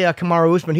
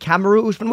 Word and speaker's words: Kamaru Usman: We say Kamaru Usman. He said, I think, Kamaru Usman. What's the proper way to pronounce it? – No, he says it that way. You guys say it correctly.